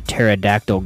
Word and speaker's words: pterodactyl: Why can't you hear a pterodactyl going to the bathroom? – I don't know. – Because the P is silent.